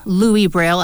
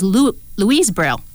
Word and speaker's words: Louis Braille: I should have picked up on it, as my screen reader did pronounce the extra word in the name, Louis Braille, as Louise Braille.